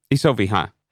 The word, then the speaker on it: Isoviha: And I told you my Finnish pronunciation is going to be terrible, but Isoviha? – Works for me, yeah. Totally. Isoviha is how I would say it, yeah. – Isoviha.